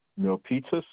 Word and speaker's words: Milpitas: Oh, M I L. P-I-T-A-S. – Yes, sir. – Okay. And 95035 is zip code. – Yes, sir. – Okay, so it's 105 Sarah Way, 179... Milpitas? – Yes, sir.